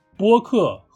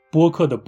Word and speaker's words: 播客: 但是我发现有很多听众朋友们都犯了一个相同的错误，大家好像有点分不清什么是播客，什么是博客。哈哈，今天我就来告诉大家，播客和博客有什么不一样。首先，这两个词的拼音是完全一样的，只是第一个字的声调不一样。播客的“ 播” 是一声。博客的“ 博” 是二声，播客，广播的“ 播”，客人的“ 客”。